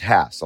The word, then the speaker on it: Hass: And it is pronounced Hass. A lot of people say Hass. Other people say Hass. There's a debate. I got a phone call from Rudolf Hass's grandson telling me specifically how to pronounce his name. It's Hass.